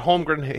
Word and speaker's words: Holmgren: But Holmgren handled it perfectly. I can say that. I didn't say the naughty one. – I'm laughing because you actually said it correctly, and I'm shocked.